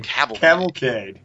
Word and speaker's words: cavalcade: That will be I can never pronounce this word. Cavalade? – Cavalcade? – Cavalcade.